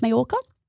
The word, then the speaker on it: Mallorca: Now, Mallorca is actually spelt with two L's. So if you see that written anywhere, that's how it's pronounced, Mallorca.